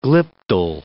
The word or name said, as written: Glyptal